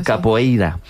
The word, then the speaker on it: capoeira: Pues todos sabemos lo que es la copeira, ¿verdad? – Capoeira. – Qué mal pronuncio últimamente. – Capoeira, ¿no? – Capoeira.